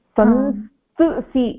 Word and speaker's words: संस्तुसि: तत्र खलु संस्तुसि इति तथा भवति संस्तुसि